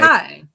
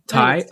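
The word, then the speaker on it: Ti: Do you pronounce it T? T? Is it T I? – Ty. – Ty. Okay.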